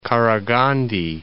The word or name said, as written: Qaraghandy